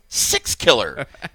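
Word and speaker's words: Sixkiller: Okay, and if you listen to Leland regularly, let me tell you, when you hear me say six killer, I'm saying six killer. As in, I don't know if it's spelled this way, but this is how it sounds. S-I-X. – It is spelled that way. – K-I-L-L-E-R.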